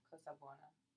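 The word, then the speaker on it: Cosa Buona: Bueno is Spanish, and Buona is Italian.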